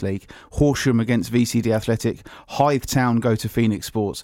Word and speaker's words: Hythe: But Hythe Town in fifth, away to fourth place Phoenix Sports. That's the game of the weekend, surely. – Yeah, I think that's well pronounced. Good pronunciation of your H's there, in that one.